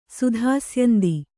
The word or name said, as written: ಸುಧಾಸ್ಯಂದಿ